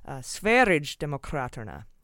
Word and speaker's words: Sverigedemokraterna: And for the pronunciation of the right-wing party in Sweden, I found out it's a, I was close, but it's a soft G, like GIF. It's not GIF, it's GIF. And that makes it Sverigedemokraterna.